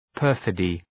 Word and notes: perfidy: ‘pɜ:rfıdı

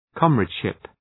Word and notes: comradeship: {‘kɒmræd,ʃıp}

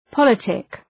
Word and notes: politic: ‘pɒlıtık

